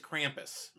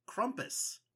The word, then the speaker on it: Krampus: One of them is Krampus. – And as I found out recently, it's actually pronounced Krumpus.